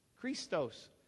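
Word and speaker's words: Christos (Χριστός): But Christ, our word Christ, is the Greek word Christ. Christ is the way they would pronounce it. Christos.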